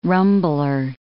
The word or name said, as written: rumbler